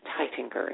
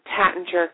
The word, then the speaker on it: Taittinger: And Claude Tatinger, because the Champagne Tatinger, and the Tatinger name is pronounced many different ways. It's originally an Austrian name, so it would have been Taittinger, and in France it's called Tatinger. In the United States we say Tatinger Tattinger, and Claude Tatinger always said it doesn't make any difference how you pronounce it so long as you reach for the bottle.